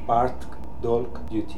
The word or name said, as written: Partkdolg duty